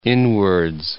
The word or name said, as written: inwards